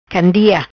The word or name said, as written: Candia